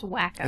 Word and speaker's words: Waco: It is Waco. – Yeah. Yeah. Essentially, it's very a Waco situation. Standoff, yeah. – It's pronounced Wacko.